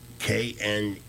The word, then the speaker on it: KNEWZ: KNEWZ News. – Oh. – The K is silent. And this also, again, it's not pro-Trump per se, but it carries stories with a straight scoop, with no political spin.